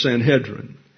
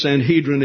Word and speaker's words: Sanhedrin: And that particular E, the segol, is pronounced as the E in bed. Therefore, the pronunciation in Hebrew is Sanhedrin. Now, in English, you have two accepted pronunciations, Sanhedrin and Sanhedrin. I go with Sanhedrin because that's the Hebrew pronunciation. I think we should stay with them. You can use Sanhedrin if you like, but the Jews say Sanhedrin, so there you go.